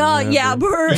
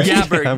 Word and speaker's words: jabber: Do you mean blabber or jabber? – No, yabber. – Jabber. Jabber with the soft J.